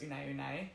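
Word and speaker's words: One One: So the Hoka, I think it's spelled 1-1, but I'm pretty sure it's Une Une.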